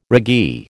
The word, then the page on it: raggee: American English